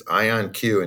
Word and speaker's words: IonQ: It is IonQ indeed. IonQ, it's based on ions, atomic ions, charged atoms that we use as our quantum transistor, if you will.